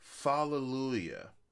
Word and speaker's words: Fallujah: Fallujah.